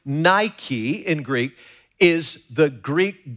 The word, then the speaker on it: Nike: Now, when the Apostle Paul wrote in Romans 8:37, under the inspiration of the Holy Spirit, that we are more than conquerors, he used the Greek word Nike. It's pronounced Nike in Greek, and it means victory.